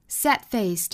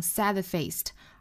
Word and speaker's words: sad-faced: Sad-faced 这个单词，我们看 sad 这个的呢，我们不能够把它爆破出来，不能读成 sad-faced，而是 sad-faced。 Sad-faced 这属于单词内部的一个不完全爆破。